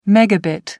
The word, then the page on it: megabit: American English